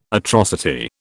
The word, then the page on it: atrocity: British English